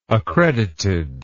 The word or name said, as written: accredited